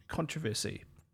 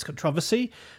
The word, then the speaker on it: controversy: Louise writes, both pronunciations are correct. The one you both used is the more modern American pronunciation, so that's controversy. The other versions, she goes on to say, with stress on con, is more traditionally British English.